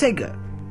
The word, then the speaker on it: Sega: The Sega Corporation, pronounced Sega, and probably styled as Sega, be a Japanese multinational video game developer, publisher, and hardware pimpment company.